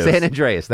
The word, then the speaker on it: San Andreas: San Andreas. That's how you pronounce it.